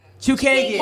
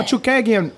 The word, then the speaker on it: Chookagian: Now, is it Chookagian? Is it Chookagian? How do you say it? Chookagian. – Oh, Chookagian. – I got it right.